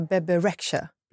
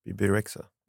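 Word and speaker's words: Bebe Rexha: Vet du hur man uttalar Bebirexha? – Bebirexha. – Bebirexha. Bebirexha. – Bebirexha tror jag.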